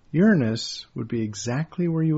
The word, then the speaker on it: Uranus: Uranus. It's pronounced Uranus.